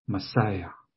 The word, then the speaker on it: Messiah: 那希伯来文的发音，这个受膏者就是 Messiah，弥赛亚。